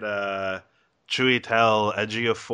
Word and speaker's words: Chiwetel Ejiofor: Yeah, get Brad Pitt in there, and get Chiwetel Ejiofor, or whatever his name is. In fact, I may have actually accidentally pronounced it correctly. I'm not sure. – You did.